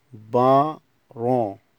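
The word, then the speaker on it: parents: الكلمة رقم 5 بارون. بارون تي والاس لا تنطق.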